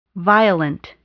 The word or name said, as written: violent